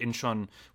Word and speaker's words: Incheon: And so then he is off where the Battle of Incheon is underway. Am I pronouncing that correctly? – Yeah, they show the Incheon Landing.